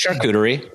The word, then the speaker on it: charcuterie: It's charcuterie.